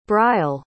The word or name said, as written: brial